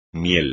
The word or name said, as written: miel